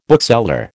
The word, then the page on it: bookseller: American English